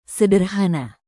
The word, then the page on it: sederhana: indonesian